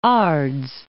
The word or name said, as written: Ards